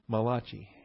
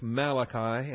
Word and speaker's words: Malachi: He calls him Malachi. And I would not want to dispute with such a learned man as Dr. Fruchtenbaum. However, from my childhood, I've always pronounced this Malachi, and I will probably default to that in the process of this teaching.